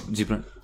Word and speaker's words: Gibran: Khalil Gibran. – Gibran.